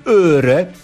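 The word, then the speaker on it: øre: Przekreślone O po duńsku czyta się jak Ö. I na przykład dawne duńskie grosze, czyli öre, tak się właśnie pisało.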